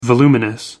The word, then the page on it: voluminous: American English